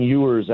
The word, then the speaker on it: Ewers: you had this Quinn Ewers out of Texas, and I hope I'm pronouncing his name right. – You are signed correct.